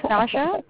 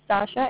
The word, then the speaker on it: Sasha: No, my name is Sasha. My name is Sasha. It's Sasha. – SASHA. Okay. – Yeah, let's pronounce it Sasha.